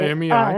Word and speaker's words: IMEI: Do I need to provide the IMA, I can never say that right, IMEI.